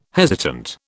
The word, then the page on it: hesitant: British English